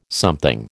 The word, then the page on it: something: American English